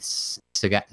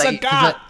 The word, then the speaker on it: Sagat: It's Sagat. It's like, that's text. That doesn't work that way. – Anyway, the way I normally say it rhymes with the word that, you know, Sagat.